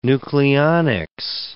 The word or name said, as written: nucleonics